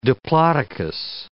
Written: diplodocus

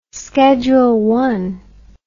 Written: scheduled territories

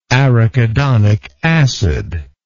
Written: arachidonic acid

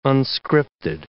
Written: unscripted

